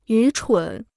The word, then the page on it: 愚蠢: yú chǔn